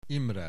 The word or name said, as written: Imre